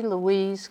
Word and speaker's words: Louise: Marjorie Louise Kuhn, M-A-R-J-O-R-I-E, L-O-U-I-S-E, K-O-O-N-E.